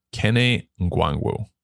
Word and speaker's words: Nwangwu: Kene Nwangwu.